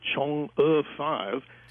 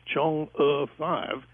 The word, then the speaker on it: Chang'e: You know, George, if you look around the internet and check, so many people are getting that wrong. And I tried to prepare for the show to make sure I tried to pronounce it right as Chang'e 5.